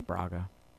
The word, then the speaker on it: Braga: I think she even says, Alice Braga? I don't know how to say her name. But it's Braga.